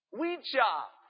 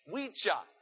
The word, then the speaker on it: Oicha: Wecha. It's spelled O-I-C-H-A. Wecha.